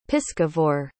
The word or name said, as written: piscivore